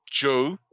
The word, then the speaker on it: Jos: Cities on the tour include Lagos, Kano, Port Harcourt, Enugu, Ibadan, Borin, Joe, is that like Joe LeDuc? Because it's spelled J-O-S, and Kaduna.